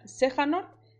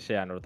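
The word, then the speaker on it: Xehanort: O sea, en japonés y en inglés se dice Seanor también. Dicen Seanor.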